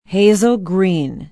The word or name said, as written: hazel green